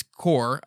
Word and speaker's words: Corps: Where it looks like corpse, but it's core.